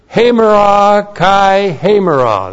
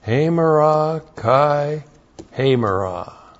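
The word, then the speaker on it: Hamera kai hamera (ἡμέρᾳ καὶ ἡμέρᾳ): This renewal and renovation of the inner man, the winner believer, is hamera kai hamera. That's how it looks in the Greek. Hamera kai hamera. H long E M E R A. Oops. Still writing in Greek. K-A-I H long E-M-E-R-A.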